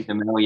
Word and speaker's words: Demel: Or was it Guy? – Demel, yep, that's right.